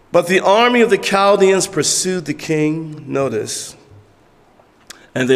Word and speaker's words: Chaldeans: And you would think it say Chaldeans, but it's Chaldeans. That's how you pronounce it.